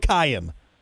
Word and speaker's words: Kyan: Did I say that right? Kyan? – Yes, it's just like Ryan with a K. – Oh, beautiful. Well, tell us about Kyan.